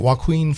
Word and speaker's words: Joaquin: I don't like that meth head Joker as the the main Joker as far as is it Joaquin? How do you say it? Joaquin Phoenix. Joaquin. Joaquin. – Joaquin yeah.